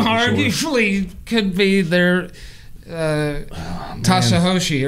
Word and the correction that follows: Satoshi: or whatever how you say it. – That's Satoshi, stacking sats.